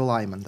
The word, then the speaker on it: Liman: Doug Liman ga je režirao, reditelj koji, ili Liman, ja volim da izgovaram Liman. – To je tvoj junak romana, ali čovjek je Liman, tako da.